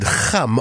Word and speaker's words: Kham: But it's more of a k sound. – Okay. – The Kham, and so the X is the kh part. But it's preceded by a k, so it's kham all at the same time.